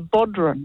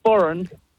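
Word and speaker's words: bodhrán: It's actually, by the spelling, you would think it's called a bodhran, a bodhran. – Mm. – But, yeah, it's pronounced boron.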